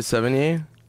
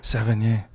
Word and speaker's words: Sevigny: Was it with Chloe Sevigny? – Ooh, you said it perfect. I've never said it right ever. – Mm-hmm. I'm very theatrical. – You are, dude. – Sevigny.